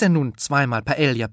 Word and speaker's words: Paella: Bei der Paella ahnen die meisten Deutschen offenbar, dass dort irgendwo ein J-Laut hingehört. Das beliebte spanische Resteessen wird jedenfalls zumeist richtig Paella ausgesprochen, sowohl mit L als auch mit J.